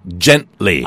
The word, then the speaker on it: gently: Gently. – G-E-N-T-L-Y인데 발음이요. Gently로 안 들리고요. Gent하고 누가 옆구리를 찌르면 gently.